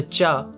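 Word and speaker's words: cha (茶): The other is some variation of cha, like chai in Hindi, zai in Russian, or kai in Turkish. Both versions come from the same Chinese character. In Mandarin and Cantonese, it is pronounced as cha.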